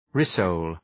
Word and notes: rissole: rı’səʋl

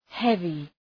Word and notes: heavy: ‘hevı

